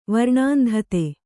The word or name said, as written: ವರ್ಣಾಂಧತೆ